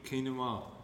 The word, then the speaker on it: quinoa: You know how quinoa is written? I called it quinoa. And Mummy looked at me and just went, you mean quinoa?